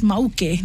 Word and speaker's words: Mauke: There was a Moke recipe book that recently launched. From the Cook Islands. Moke is an island there. And they pronounce it as Mauke, ne? So like Mauke, nice.